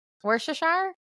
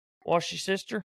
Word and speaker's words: Worcestershire: How do you say that one sausage you put on steaks? – Worcestershire? – Is that how you say it? – Worcestershire? – Wash your sister.